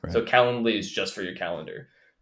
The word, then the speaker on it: Calendly: Calendly or whatever, right? Or yeah, go ahead. Calendly. Sorry. I don't know why I put the the wrong emphasis on the wrong syllable.